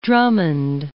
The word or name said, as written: Drummond